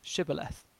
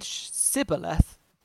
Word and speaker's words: Shibboleth: If he replied no, they said, "All right, say Shibboleth." If he said Sibboleth, because he could not pronounce the word correctly, they seized him and killed him at the fords of Jordan.